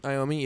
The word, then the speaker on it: Iommi: Tony Iommi? Is that how you say it? – Iommi, yeah.